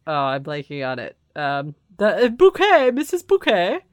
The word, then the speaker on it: Bucket: It's bouquet, B-U-C-K-E-T, bouquet. The accent on the second syllable.